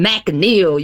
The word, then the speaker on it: McNeail: You got to say Antoine McNeil. You got to get the McNeil.